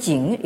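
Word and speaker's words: Jing (井): qui se prononce donc en chinois moderne Jing et qui veut dire le puits.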